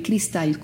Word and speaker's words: Cristal: Y es, pues mi nombre se escribe Cristal con I latina, ¿sí? – Como cristal.